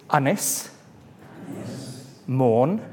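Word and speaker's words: Ynys Môn: His constituency is Ynys. Mourne.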